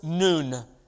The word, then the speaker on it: Noon: That letter is the 14th letter of the Arabic alphabet, and it's pronounced Noon. N-O-O-N, and it's the N,